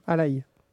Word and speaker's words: Alaj: Alaï. Ça s'écrit Alaj, mais ça se prononce Alaï.